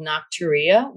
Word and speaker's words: nocturia: Well, peeing in the middle of the night is called nocturia, which at least is kind of a pretty sounding word. So there's that. – It kind of rhymes with gonorrhea.